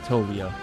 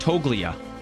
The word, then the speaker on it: Toglia: Yeah, I believe Tolia. – Toglia. So the G is silent there. – Yeah. – Interesting. Kind of wish the G wasn't silent there. Kind of like Toglia.